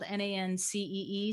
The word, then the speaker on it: Nancee: My name is spelled Nancee.